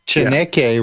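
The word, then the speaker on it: Chaneque: like the I'm not going to be able to pronounce it correctly. The what is it? The Alux and the Shanike. – The Alush and the Chaneke.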